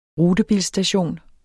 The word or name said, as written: rutebilstation